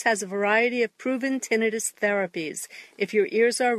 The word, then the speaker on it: tinnitus: It's your tinnitus, actually pronounced tinnitus.